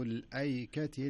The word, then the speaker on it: الأيكة: هي تكتب بهذه الطريقة، هي تكتب بهذه الطريقة، لكن لابد تنطقها كذب، كذب أصحاب الأيكة المرسلين.